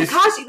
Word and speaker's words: Tekashi: Tekashi. – Yeah. Is that how you say it? – Yeah. – Tekashi.